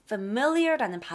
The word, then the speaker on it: familiar: 이 familiar라는 발음으로 돼요. Familiar. 그랬지, 그랬지? You look familiar. 그래요. Familiar. 아니야. Familiar. 자, f, me. 에서 소리 밀어주시면 돼요. Familiar.